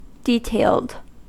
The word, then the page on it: detailed: American English